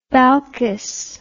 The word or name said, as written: balkis